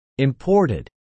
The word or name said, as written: imported